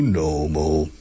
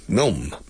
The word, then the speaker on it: Nome: Although the spelling is different than a gnome. You see, a gnome, so you gnomo. Los gnomos del bosque se escribe gnome con G muda al principio. Gnome, gnome. But the city of Nome in Alaska doesn't have the G. It's Nome. N-O-M-E.